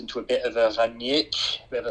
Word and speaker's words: Rangnick: So we did Rangnick. Rangnick. Ralf Rangnick. – Rangnick. Yeah. – You need your emphasis on the first part of the word. So Rangnick.